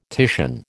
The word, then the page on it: Titian: American English